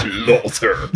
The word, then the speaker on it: Slaughter: Slaughter. – Ale z brytyjskim akcentem brzmi jak Slaughter. Slaughter.